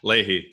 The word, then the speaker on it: Leahy: Leahy.